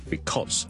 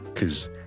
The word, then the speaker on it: because: ，because 係 be 啦，第一個 vowel 係 e 啦，第二個 vowel 係 o，跟住後面係一個 z 音，because，但係咧喺口語嘅時候咧，尤其是當呢個字唔係咁緊要嘅時候咧，我哋可能會讀得好快好快。大家都可能会见过 COZ 呢个间隻 ,ok? 呢个系个读法呢可能系就纯粹呢系 cause,